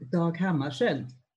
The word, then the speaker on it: Dag Hammarskjöld: På svenska uttalar man namnet Dag Hammarskjöld.